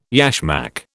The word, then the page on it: yashmak: British English